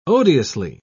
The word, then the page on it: odiously: British English